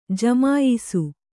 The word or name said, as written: ಜಮಾಯಿಸು